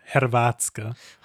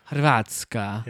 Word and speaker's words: Hrvatska: Oh, Hrvatska. – Hrvatska.